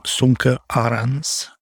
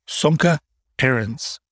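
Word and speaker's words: Sönke Ahrens: This is all coming from a book that I just finished, which is called How to Take Smart Notes by a guy named Sumke Arends. Or if you want to read it phonetically American instead of German, Sumke Arends.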